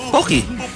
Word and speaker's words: Pocky: Pocky bang ang pronunciation nun? – Ano ba? – Pocky. – Yeah. – P-O-C-K-Y yun, di ba? – Pocky.